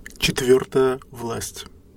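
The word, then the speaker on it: Четвёртая власть: This is how you say that correctly. – Четвертая власть.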